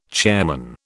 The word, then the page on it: chairman: British English